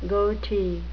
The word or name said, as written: goatee